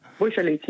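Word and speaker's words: Bouchallikht: Je hebt dat samen gedaan met Kautar Bouchalikit. Zeg ik het goed? Ik zei niet goed, hè? – Bouchalikit, ja.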